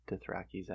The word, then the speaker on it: Dothraki: Sorry to the Dothrakis out there. Dothraki. I heard Germ pronounce it that way. It's Dothraki.